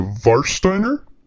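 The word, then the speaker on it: Warsteiner: Is it Germany that pronounces W's with like a V? – Yes. So that'd be a Varsteiner.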